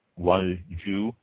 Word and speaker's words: Baekhwaju: There is a special flower wine that is called, and I will pronounce this wrong, I know I will, spelled B-A-E-K-H-W-A-J-U by Haekwaju.